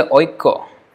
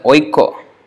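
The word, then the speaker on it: Oikko (ঐক্য): Spelling is always like Aikya. But the pronunciation is as Oikko. Actually Oikko. Oikko.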